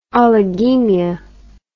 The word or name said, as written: oligemia